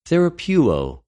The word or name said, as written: θεραπευω